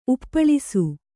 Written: ಉಪ್ಪಳಿಸು

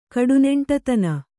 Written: ಕಡುನೆಂಟತನ

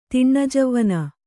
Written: ತಿಣ್ಣಜವ್ವನ

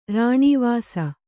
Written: ರಾಣೀವಾಸ

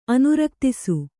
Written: ಅನುರಕ್ತಿಸು

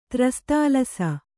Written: ತ್ರಸ್ತಾಲಸ

